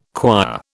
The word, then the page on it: choir: British English